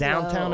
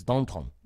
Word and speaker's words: Downton: Downtown Abbey for years. It's Downton.